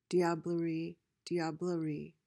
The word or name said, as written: diablerie